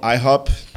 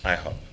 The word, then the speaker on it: IHOP: como é que eles pronunciam? IHOP ou... – Eles falam IHOP. – IHOP okay.